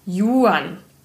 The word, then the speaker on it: Juan: Das ist Spanisch. Da spricht man das J wie ch aus. Einer der vielen Gründe, warum diese Sprache so unverschämt feurig wirkt. Juan.